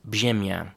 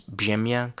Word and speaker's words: brzemię: Well, if you see it on paper, it's spelled brzemie with a little hook on the bottom of it, and that rz in Polish is pronounced zje. Sorry if my Polish pronunciation is not amazing. So burden in Polish is brzemię, and in Russian it's bremia, and in French, it is fardeau.